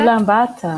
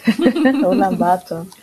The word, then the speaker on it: Ulaanbaatar: Ulaanbaatar. – Ja. – Ulaanbaatar.